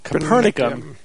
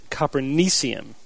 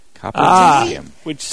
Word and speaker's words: Copernicium: We thought it should have been Copernicum. – Copernicus, yeah. – But it seems that the proper pronunciation is Copernicium. – Copernicium.